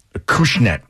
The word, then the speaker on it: Acushnet: Acushnet. – I think it's Acushnet. That's how you say it.